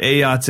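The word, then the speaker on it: Ayotte: How do you say, is it Ayot or Ayoti? – Ayot. – Ayot, okay.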